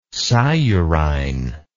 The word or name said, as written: sciurine